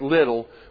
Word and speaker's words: Liddell: Eric Liddell, L-I-D-D-E-L-L, but pronounced Little, was born in 1902 in Tientsin, China.